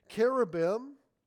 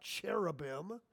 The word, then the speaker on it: cherubim: cherubim, as it should be pronounced, not cherubim. You know what cherub is? Fake chocolate? Good, better for you, some people say. It's not cherubims.